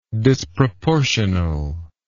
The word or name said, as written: disproportionally